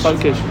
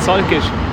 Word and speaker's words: Salgesch: Ich habe den Namen von diesem Dorf x-mal probiert zu sagen mit ihm und er hat immer wieder gesagt, nein, so spricht man es nicht aus. Salgesch? – Salgesch.